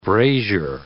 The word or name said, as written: brazier